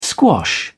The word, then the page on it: squash: British English